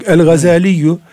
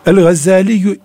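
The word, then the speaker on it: el-Gazzâlî: El Gazaliyu El Gazali diyoruz ama asıl adı Gazali. Hmm. Türkçe'ye şeddesiz olarak geçmiş ve e, galati meşhur daha evla diye öyle telaffuz ediyor. El Gazzaliyu imamun kebirun.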